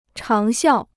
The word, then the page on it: 长效: cháng xiào